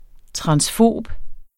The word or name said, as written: transfob